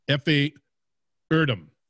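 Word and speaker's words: Efe Erdem: And you're pretty, I mean, if I found you and correct me, it's Effie Erdem.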